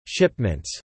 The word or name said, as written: shipments